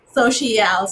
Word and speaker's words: Sociale: Sociale. So if you break it down phonetically, so C L is it's S O hyphen S H E hyphen A L L.